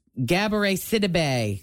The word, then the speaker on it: Gabourey Sidibe: Gabourey Sidibe.